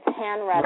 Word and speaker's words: Hanretta: Bridget can obviously be found on Facebook, and her name being Bridget O'Brien is spelled O-B-R-I-E-N. Now, you need to tell me your middle name because I've never known how to pronounce it, but they won't find you unless I give the middle name. – Okay, that's my maiden name. It's Hanretta, right. – H-A-N-R-E-T-T-A.